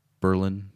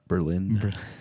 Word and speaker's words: Berlin: I believe it's pronounced Berlin, not Berlin. That Berlin's in Germany.